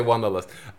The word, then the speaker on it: Wanderlust: Wanderlust, like in German? The word? – No, you say Wanderlust.